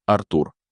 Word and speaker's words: Артур: Его звали Артур, ударение на первую А, Глебович Ней.